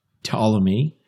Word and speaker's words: Ptolemy: And Ptolemy, with the silent P, he's an astronomer.